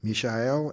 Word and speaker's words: Mishael: Among these from the people of Yehuda were Daniel, Hananiah, Mishael, and Azariah. That's how you pronounce their name in Hebrew.